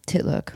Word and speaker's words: Titlook: And I started this app, a dating app called Titlook. And what it is. – Sorry, come again? What was it called? – Titlook. – How do you spell that? – T-I-T-L-O-O-K.